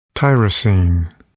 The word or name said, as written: tyrosine